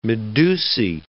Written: medusae

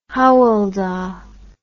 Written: Hywel Dda

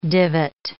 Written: divot

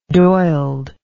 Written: doiled

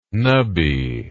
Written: knubby

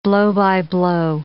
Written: blow-by-blow

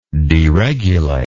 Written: deregulatory

